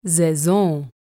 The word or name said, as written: Saison